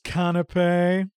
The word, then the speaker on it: canapé: Also, Peter, it's canapé. – Sorry. – You troll. – I knew I was saying that wrong.